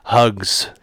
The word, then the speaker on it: Hux: According to the book, he pronounces it Hugs. – Hugs. – With a G.